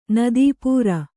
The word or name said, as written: ನದೀಪೂರ